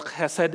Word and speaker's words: chesed: The first word is pronounced chesed in the Hebrew, meaning unfailing or steadfast love.